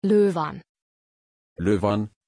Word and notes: loëvan: German

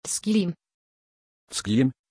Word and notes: Zgjim: German

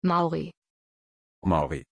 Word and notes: Mauri: German

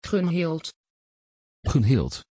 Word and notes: gunhild: Dutch

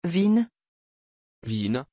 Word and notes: vinn: French

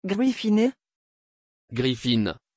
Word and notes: gryffin: French

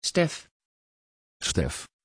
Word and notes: Stef: Dutch

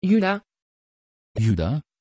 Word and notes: juda: Dutch

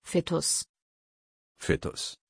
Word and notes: vittus: German

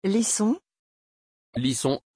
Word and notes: Lyson: French

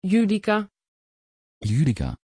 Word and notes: judika: Dutch

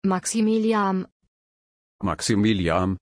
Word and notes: Maximiliam: German

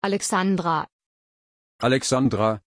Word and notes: Alexandra: German

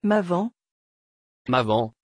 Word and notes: Maven: French

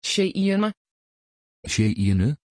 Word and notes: cheyenne: Dutch

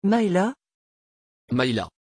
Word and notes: Mayla: French

